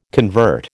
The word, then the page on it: convert: American English